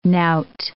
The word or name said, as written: knout